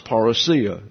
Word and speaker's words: parousia: Now, if you go to the dictionaries, which I do, there is an English transliteration of this word, and it is pronounced parousia, and that the Greek shows you that pronunciation, parousia.